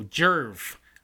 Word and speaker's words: Djerv: But right now, this Jev. I'm sure I'm not pronouncing that right, but it is D-J-E-R-V. So Jerv.